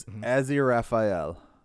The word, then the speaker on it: Aziraphale: So that the one dude's name, how you pronounce it, is Aziraphale. – Okay, there you go. Because I think they say it like Asaferol or Asaferiel or something like that in the show. I don't know, but it's really hard. Like they, well, the proper pronunciation of it is what I said.